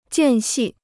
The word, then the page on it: 间隙: jiàn xì